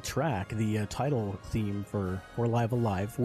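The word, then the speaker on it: Live A Live: which that is the official pronunciation. I heard on the Kit and Krista show recently that for a while there was no real official English pronunciation of the game, but somebody at the Nintendo Treehouse said that, well, officially, when it's talked about in English by, you know, people in Japan, it's pronounced Live Alive.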